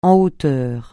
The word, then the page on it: en hauteur: français